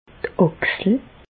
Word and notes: d' Àchsel: Bas-Rhin; Reichshoffen

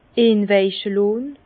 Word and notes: inwaiche lohn: Bas-Rhin; Herrlisheim

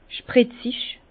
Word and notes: spritzig: Bas-Rhin; Herrlisheim

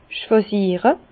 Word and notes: schwàsiere: Bas-Rhin; Herrlisheim

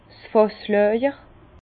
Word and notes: 's Fàsslàjer: Bas-Rhin; Herrlisheim

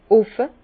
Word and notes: offe: Bas Rhin; Herrlisheim

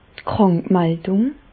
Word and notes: d' Krànkmaldùng: Bas Rhin; Reichshoffen

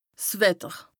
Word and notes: 's Wetter: Bas Rhin; Strasbourg

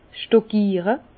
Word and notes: 's Stockiere: Bas-Rhin; Herrlisheim

